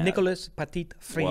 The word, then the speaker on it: Petit-Frere: And then I think that's got to be pronounced more French. Yeah. Nicolas Petit-Frere.